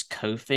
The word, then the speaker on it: Ko-fi: So that's on, I believe it's Ko-fi you pronounce it.